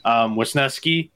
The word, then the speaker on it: Wesneski: Wesneski is the way to pronounce it.